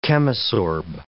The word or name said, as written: chemisorb